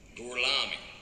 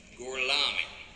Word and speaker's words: Gorlami: Gorlomi? Lo pronuncio correttamente? Sì, corretto. Gorlomi? Per cortesia, me lo ripeti ancora. Gorlami. Mi scusi con me? Gorlami. Ancora una volta. Gorlami.